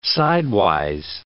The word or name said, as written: sidewise